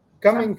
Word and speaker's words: Cuming: Am I saying it's the Cumming County Fair or Cumin County Fair? How do you say that? – Cuming County. Yep. – Cuming County. Okay.